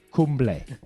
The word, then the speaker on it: Kumble: Arnel Kimball. Kimball. I can't pronounce his name properly. – Kumble. Anil Kumble. Thanks, Steve.